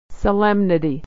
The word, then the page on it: solemnity: so-lem-ni-ty